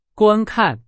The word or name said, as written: 观看